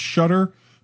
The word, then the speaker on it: Shuttr: It's the now how do you I you know uh, Muku Labs, you know, S H U T T R, Shutter. I'm sure, I'm sure it's pronounced Shutter, but when I first look at sure.